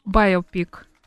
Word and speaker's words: байопик: Ну, скажи правильное ударение. – «Байопик». – «Байопик», «Байопик», извините, «Байопик», да, «Байопик». Виолетта, телефон пришлите.